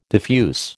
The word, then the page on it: diffuse: American English